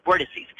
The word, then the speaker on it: vortices: And that's why we're considered this, this area has vortexes or vortices. Is the pro, uh, proper way to say it, vortices.